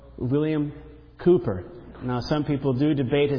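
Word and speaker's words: Cowper: Just so you know, there was a letter found of one of his friends that said that the way that his relatives and close friends pronounced his name was Cooper, with two O's. Though it's spelled C-O-W, it's pronounced Cooper, according to his friends and relatives.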